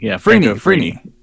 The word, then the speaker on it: Frini: It was also co-written by Franco Ferninan. Say that right? – Frini. – Frini. My bad. – Yeah. – Franco Frini.